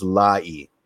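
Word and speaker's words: Laryea: He's not picky in terms of whether it's Larea or Lai. Lai is the Ghanaian pronunciation and how, you know, his I guess family would go by it, but he's lived in Canada for years and years and years and it's always gone by Larea.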